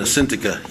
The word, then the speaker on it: Syntyche: Yeah, it's Eudia and Syntyche.